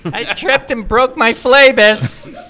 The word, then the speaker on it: Phlebas: Consider, is it Phlebas? – I pronounce it Phlebas, but Phlebas. – I don't know.